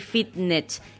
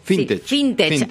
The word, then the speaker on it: Fintech: La norma busca facilitar la renegociación de créditos de familias y empresas con entidades bancarias, financieras y Fitnet. ¿Se dice así? – Fintech.